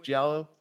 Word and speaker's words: Giallo: Am I pronouncing that correctly? Giallo? – American Giallo.